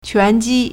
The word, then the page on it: 拳击: quánjī